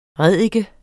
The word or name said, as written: ræddike